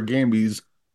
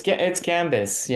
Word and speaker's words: Gambis: And it's Alexis Gambis or Gambies? – It's Gambis. Yeah, it's a okay. – French name. We have odd consonants that we pronounce and we don't pronounce, but it's Alexi Gambis.